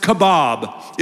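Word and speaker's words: kavod: Well, it's pronounced kabob.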